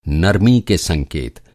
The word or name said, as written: संकेत